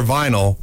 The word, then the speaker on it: vinyl: Remember, for fencing, DLR vinyl rhymes with final.